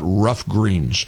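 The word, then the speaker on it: Ruff Greens: I'm talking, of course, about Rough Greens. Rough Greens is spelled R-U-F-F. Rough Greens, all natural, made in the USA.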